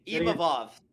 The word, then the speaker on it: Imavov: Imanov, yeah. – Imavov. There's no N in it.